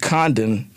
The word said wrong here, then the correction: Dundon: Tom Dundon,